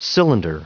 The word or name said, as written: cylinder